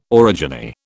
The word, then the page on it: orogeny: British English